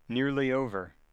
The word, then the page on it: nearly over: American English